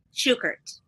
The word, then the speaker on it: Shukert: First of all, am I pronouncing your name? Is it Shukert? – Shukert. – Shukert. Okay. We have been pronouncing it correctly.